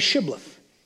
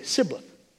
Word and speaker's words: Shibboleth: Then they would say to him, "Then say Shibboleth." And he would say, "Sibboleth." For he could not pronounce it right.